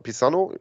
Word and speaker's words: Pisano: Alexandro äh, Pizzano Pisano, ja, habe ich richtig gesagt, äh, fragt, wie ist es erfahrungsgemäß mit Karten für London?